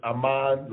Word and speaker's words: Amon: His name is Amon, like Common. It's like Common is pronounced Amon.